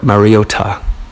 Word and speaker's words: Mariota: Marcus Mariota. That's how you pronounce it for real. Mariota.